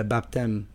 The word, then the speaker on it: baptême: Puis je l'écoutais, puis j'avais une question sur le baptême. Baptême? – Baptême. baptême. On ne prononce pas le P.